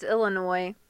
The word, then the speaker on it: Illinois: All jokes aside, there is the S is silent, is it not? – Yeah. – Illinois. – It's Illinois.